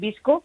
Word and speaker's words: VSCO: Vale, una es VSCO, también le dicen Visco, pero se escribe V-S-C-O.